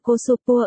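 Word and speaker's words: cosopua (コスプレ): được phát âm là cosopua ở Nhật. Wiki, không phải là truyền đạt di chúc sao?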